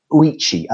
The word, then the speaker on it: Ouchi: But the one that came out of Japan was by William, I'm not sure how to pronounce his surname, I think it's Ouchi, O-U-C-H-I, and it's called Theory Z.